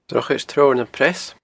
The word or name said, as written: Drochaid Treabhar nam Preas